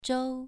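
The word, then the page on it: zhou: tone 2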